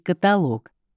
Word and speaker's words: каталог: Каталог или каталог. С точки зрения орфоэпии правильно звучит каталог. А другая форма постановки ударения в словарях указывается как устаревшая.